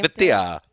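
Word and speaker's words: Vaitai: But Vitae, do you know how to pronounce his name? – Vitae. – Yes, that's it. Vitae.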